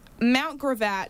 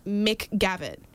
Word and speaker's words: Mount Gravatt: However, Mount Gravatt, how did you get McGavitt? She said McGavitt. Where is the C coming from? Yeah. – Where's the C coming from? Where did the R go? – Where'd the mountain go?